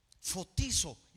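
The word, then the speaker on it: fotizo (φωτίζω): La palabra alumbrar viene del griego fotizo con Z. Fotizo y acento en la I.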